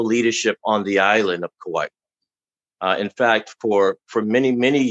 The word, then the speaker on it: Kauai: The Kauai Fatherhood Council is hosting a men's conference. Am I saying that correctly? – Yes, you are, sir.